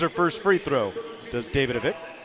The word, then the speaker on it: Davidovich: That's a very Americanized pronunciation of that name because it is spelled D-A-V-I-D-O-V-I-C. And you're right, Davidovich, like Lolita Davidovich, the actress.